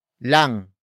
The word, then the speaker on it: lang: Word for word, it's kakainom lang niya ng kape.